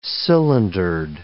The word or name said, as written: cylindered